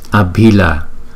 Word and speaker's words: Abhila: Cách đọc câu chú này là Om Om Om Akini Akini Akini Nikani Nikani Nikani Abhila. Chữ B đứng một mình chúng ta đọc là B. Chữ B và chữ H đứng chung chúng ta đọc là B. Abhila, Abhila, Abhila.